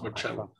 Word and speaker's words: Marcello: Yes, it's Marcello, but the Italian is Marcello.